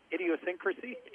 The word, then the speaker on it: idiosyncrasy: Little bad habits, Synchrosyncrasies or whatever. – How do you say that? – Synchrosyncrasies. – That's a new word. Webster. We're calling Webster's idiosyncrasy. – Idiosyncrasy.